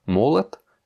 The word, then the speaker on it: молот: Молот, ben là encore, on a un O non accentué qui se prononce comme un A.